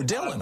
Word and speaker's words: Dylan: It is D-Y-L-A-N. It's Dillian. – Oh, Dillian, I'm sorry.